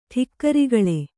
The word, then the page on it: ಠಿಕ್ಕರಿಗಳೆ: ṭhikkarigaḷe